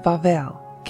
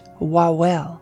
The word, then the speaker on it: Wawel: The W is pronounced like the English V, so instead of pronouncing it as it looks to us in English, Wawel, it is actually pronounced Wawel.